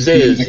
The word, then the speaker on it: Ziz: Do you think that that is Ziz? He actually says it rhymes with Fizz.